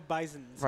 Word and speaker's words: Bisons: The Buffalo Bisons. – Right. It's not bison, which is grammatically correct. It's not bisons, which sounds correct.